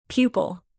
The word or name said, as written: pupil